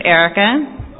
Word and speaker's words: Ericka: Okay, my name is Erica. E-R-I-C-K-A. My last name, I'm just going to spell it. It's Pelicowitz. It's P-E-L-E-C-H-O-W-I-C-Z.